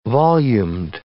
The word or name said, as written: volumed